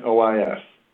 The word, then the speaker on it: Livernois: Livernois. – Yeah, and that S is silent in case you heard me say that at the beginning of the podcast.